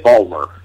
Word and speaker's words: Baltimore: How do they say it? Say it correctly for me. – Well, they always say they say it Balmer. – Balmer. Now, there you go.